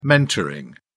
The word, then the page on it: mentoring: British English